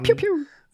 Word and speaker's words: Pugh: die heißt Florence Pugh, also wie Pugh, so wird das ausgesprochen. Pew, Pew.